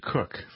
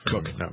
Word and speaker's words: Koch: Jim Koch, I think he pronounces it Cook. – Cook.